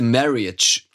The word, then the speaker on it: marriage: To celebrate the marriage. – The marriage. – The marriage. – Marriage, nicht marriage, marriage. – Echt? Da habe ich das ja Jahrzehnte falsch ausgesprochen.